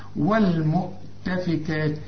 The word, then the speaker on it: والمؤتفكات: والمؤتفكات. الكاف بدون ألف بس رسم لك عليها ألف عشان تنطقها بالألف، والمؤتفكات.